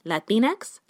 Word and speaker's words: Latinx: But using a slash is clunky, and while there has been criticism that it's not clear how to pronounce Latinx, many say Latinx, like Kleenex, it's even less obvious how to utter commercial A.